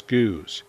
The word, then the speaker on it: Gueuze: known as Guse. That's spelled G-U-E-U-Z-E, and is the French name for the style.